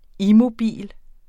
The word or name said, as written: immobil